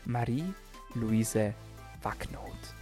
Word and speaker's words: Marie Luise Wackenhut: Und ich sage herzlich willkommen bei DailyMed Marie Luise Wacknoth.